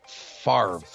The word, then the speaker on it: Favre: I'm looking it up on Wikipedia right now, and it's pronounced, well, it's spelt Favre. – Favre. – Not Favre.